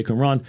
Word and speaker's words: Qumran: that is how you say Qumran, uh, Neoplatonism, uh, which would, uh, it's a later development also around the time of Augustine, and uh, then Manichaeism.